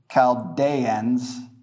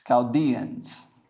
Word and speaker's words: Chaldeans: in the land of the Chaldeans, I mean, you wouldn't say Chaldeans, it's Chaldeans, because we're speaking English.